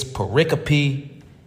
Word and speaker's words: pericope: And the word of the week is pericope, which is spelled P-E-R-I-C-O-P-E, pericope.